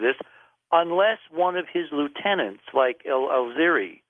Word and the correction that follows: Zawahiri: I keep mispronouncing his name. – Zawahiri.